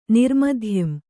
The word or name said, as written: ನಿರ್ಮಧ್ಯಂ